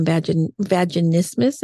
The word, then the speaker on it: vaginismus: But, you know, what a better relationship than you currently have if you're suffering from vaginismus. Did I say this correctly? – Yes. – Okay. – Yes, good job.